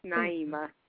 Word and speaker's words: Naima: It's Naima.